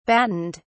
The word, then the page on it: battened: British English